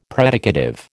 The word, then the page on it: predicative: American English